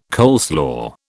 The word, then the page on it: coleslaw: British English